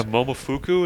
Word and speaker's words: Momofuku: Yeah, Momofuku, is that how it's pronounced? – Yeah.